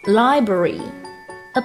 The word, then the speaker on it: library: 读快了的话是很容易读秃噜嘴，所以大家一定要慢点读，把每个音都发清楚。读作 library，a public library，公共图书馆。